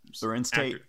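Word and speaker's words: Larenz Tate: And so in the scene you have Lorraine, I don't know how to pronounce this Lorenz Tate.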